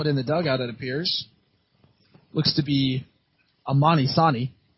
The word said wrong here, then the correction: Amari Sani: Amari Sani, my apologies. That mispronunciation. So he'll be over there at first, running for Jake Rogers as he takes his lead.